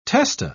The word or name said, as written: testa